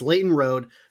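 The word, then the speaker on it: Lleyton Roed: And then your player to watch is, you know, someone who, with just an absurd name, just spelling wise, but it's Leighton Rhode, spelled L-L-E-Y-T-O-N and then R-O-E-D. I mean, just spell it phonetically, please, but, you know, to each their own, of Bemidji State.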